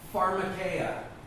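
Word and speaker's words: pharmakeia (φαρμακεία): And the Greek word pronounced here is pharmakeia. What does that sound like? – Pharmacy. – Exactly.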